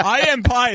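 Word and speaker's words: Pei: I am Pie is it's not spelled the correct way if you're going for pie, but it's pronounced pie.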